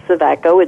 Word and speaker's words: Sevacko: It's just Michele Sevacko. It's Michele with one L. And my last name is spelled S-E-V-A-C-K-O.